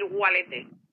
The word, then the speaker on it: valete: Eh, la V se pronuncia como una U y para decir adiós en general, pues podemos decir Walete.